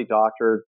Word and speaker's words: Doktor: It's R-A-Y-D-O-K-T-O-R. And that's K as in King Kong. So my last name is really Doctor, D-O-K-T-O-R.